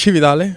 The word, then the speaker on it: Cividale: Cividale. – Cividale.